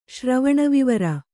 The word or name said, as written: ಶ್ರವಣವಿವರ